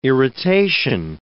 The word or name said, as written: irritation